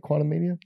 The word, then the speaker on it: Quantumania: Am I saying that right? Quantumania? – Yeah.